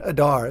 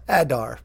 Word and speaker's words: Adar: I'm going to say his name right, because I'm always saying Adar. It's Adar.